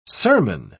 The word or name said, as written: sermon